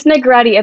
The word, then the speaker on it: Negrete: It's Negrete. It's like spaghetti.